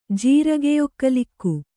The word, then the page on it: ಜೀರಗೆಯೊಕ್ಕಲಿಕ್ಕು: kannada